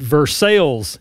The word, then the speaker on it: Versailles: But I will say it's pronounced Versailles in Kentucky, even though the place in France might be Versailles.